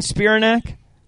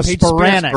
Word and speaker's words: Spiranac: Is he saying Spiranac? That's what he's saying? – No, Spiranac. – Spiranac. – Paige Spiranac.